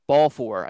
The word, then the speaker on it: Balfour: Also what helped was that his Belfour's, I think it's actually pronounced Balfour, I think. – Since you know that? – Yeah, I mean I've heard both.